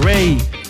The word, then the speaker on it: Raye: Rey. Se escribe Raye, R-A-Y-E, con esta canción que se llama I Know You're Hurting.